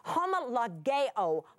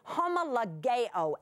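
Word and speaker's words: homologeo (ὁμολογέω): That word confession and confess comes from that same lexical term, homologeo, homologeo, H-O-M-O, L-O-G-E-O, and that final O is a long O, homologeo. And it is a word to mean that H-O-M-O is going to be together or the same, and the logeo means to say.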